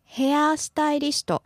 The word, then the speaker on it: hairstylist: Hairstylist. Some people prolong a sound and say hairstylist. So your pronunciation is good.